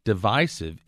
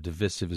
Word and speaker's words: divisive: Divisive is, in fact, the preferred traditional pronunciation of the word. Divisive is rather newish and considered not as good.